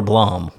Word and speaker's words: Blome: B-L-O-M-E, so Bloom or Blom? – Blom.